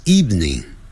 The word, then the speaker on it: evening: 状语啊或者这些副词啊这些短语啊是放在句尾 Tomorrow 也是这样子明天 T-O-M-O-R-R-O-W 你明天想要做什么 What would you like to do tomorrow 你今天晚上想要做什么 What would you like to do this afternoon this evening evening 就是晚上 E-V-E-N-I-N-G 下午是 afternoon evening 这个呢我们很容易读错哦 E-V-E-N-I-N-G 我们可能会读成三个音节